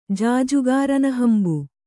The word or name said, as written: ಜಾಜುಗಾರನಹಂಬು